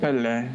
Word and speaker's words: Pelle: Uh, one of their friends from grad school is a, I guess, a, uh, a, a Swede named is it Pelle or Pel, uh, Elijah? – Or I think it's Pelle. Yeah, you, Pelle. you, you kind of pronounce the L uh, Pelle, at yeah.